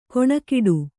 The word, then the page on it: ಕೊಣಕಿಡು: Kannada; koṇakiḍu